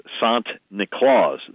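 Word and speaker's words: Sint Niklaas: And we pronounce Saint Nicholas Sant Niklaus, the Dutch pronunciation, or Santa Claus.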